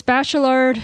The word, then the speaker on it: Bachelard: And is it Bachelard? Is that how you say it? It's Bachelard.